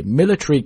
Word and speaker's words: military: 给 military commanders。 military 这个单词的意思表示军事的都没问题，但大家可能刚才听我读的时候我把它读成了 military, 啊这个也没有问题。也就相当于是以 T, 然后后面加一个元音，然后 R 一个这种搭配的字呢，你可以把它读全，就是 military, 但是呢你同样也可以把中间的那个元音给它省掉，直接以这个 TR 作为一个音节来读，所以可以读成 military, also you can pronounce like a military, 都行。